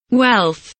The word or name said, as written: wealth